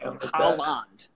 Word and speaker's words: Haaland: It's Haaland.